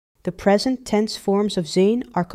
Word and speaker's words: zijn: Zijn, zijn, zijn. Notice the long A sound from the IJ digraph. That's two letters working together to make one sound. And notice the soft N at the end.